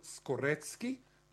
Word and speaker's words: Skorecki: Karl Skorecki, and he pronounces the C's as tz. It's not like Moroccan, which is Mrocki, or it's not like Slaki, which is Slutsky. He's a real Skorecki, and he is an expert of that.